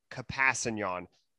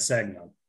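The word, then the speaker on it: Kpassagnon: It is Tano Kapasanyan. You got to put a lot of love on that K.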